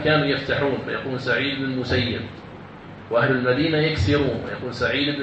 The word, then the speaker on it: المسيب: قال وحدثني عن مالك عن عطاء ابن عبد الله الخراساني عن سعيد بن المسيب، سعيد بن المسيب، سعيد المسيب يعني تنطق بكسر الياء مع تشديدها المسيب، وبعضهم ينطقها المسيب سعيد بن المسيب، لكن سعيد رحمة الله كان يكره الفتح، كان يكره الفتح، إذن فالأفصح أن تنطق سعيد بن المسيب عليه رحمة الله.